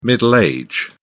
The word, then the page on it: middle age: British English